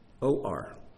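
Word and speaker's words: or (עור): It's also pronounced or. It has the exact same pronunciation. All that has changed is the first letter.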